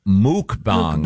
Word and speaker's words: mukbang: No, the word, and it's pronounced mukbang. – Mukbangs. – Mukbang. – Mukbang. – Yeah. It's actually pronounced muk. Yeah. – Is it muk? – Mukbang. – Yep. Oh, I thought it was mukbang. – The pronouncer here is M-O-O-K.